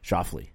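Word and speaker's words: Schauffele: How do we say that guy? – Fat-titted bastard. – Shoffley. – Yep. Xander Shoffley.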